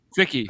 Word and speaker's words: Cichy: Linebacker for Tampa Bay, Jack C-I-C-H-Y. – Sicky.